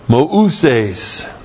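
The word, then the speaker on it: mouses (Μωυσῆς): And kathoper means, it sets up a comparison and you translate it just as or like, and then the proper noun, mouses. M long O U S long E S. So you thought Moses, you didn't know, that's really what Moses looks like. M O U S E S. Moses.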